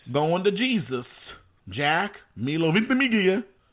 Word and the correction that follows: Ventimiglia: Excuse me, Ventimiglia. Because the G is silent, so get rid of it.